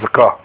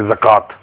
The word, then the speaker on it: zakah: That's why it's called zakah. Okay? We in Urdu say zakat. With the pronunciation of ta, but it means zakah, because I mean you don't say the ta.